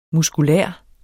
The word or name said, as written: muskulær